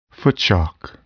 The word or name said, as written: footshock